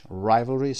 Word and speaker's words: rivalry: نمبر 3 یک اسم هست، competition between two people، رقابت بین دو نفر هست که گفتیم rivalry هست، تلفظش rivalry stress در قسمت اول را، rivalry رقابت.